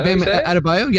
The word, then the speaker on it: Bam Adebayo: I'm going to go, I don't even know how to say his last name. Bam Adebayo? – Yeah, Bam Adebayo.